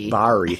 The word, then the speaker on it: Bari: you'll see that they have Berry, and of course Berry. – Bari. – Thank you.